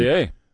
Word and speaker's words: Bossier: Bossier.